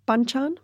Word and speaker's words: banchan: Let's talk a bit about um, how do I pronounce this? Ban banchan. Mm. Banchan. Perfect.